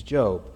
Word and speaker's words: Job: It's spelled like Job, but his name is Job.